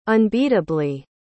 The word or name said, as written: unbeatably